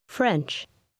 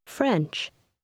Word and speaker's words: French: French. French. – Faites bien attention à la prononciation du R américain dans ce mot. Dites encore une fois le français. – French.